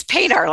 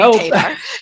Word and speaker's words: Padar: It's Padar like radar, but it's Jody Padar.